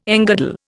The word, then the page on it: engirdle: Indian